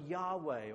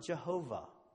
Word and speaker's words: Yahweh: just know that it's a translation of the Hebrew word Yahweh, or as some people pronounce it, Jehovah. The same name pronounced two different ways. Sometimes it's contracted to the word Yah, Y-A-H or J-A-H.